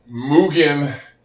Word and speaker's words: Mugen: We signed a distribution deal with Mugen.